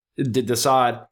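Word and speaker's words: De Sade: And then just the last two songs of Desade or Desad, I don't even know how to pronounce that. And then the Desad. – Pronounced Desad, yeah.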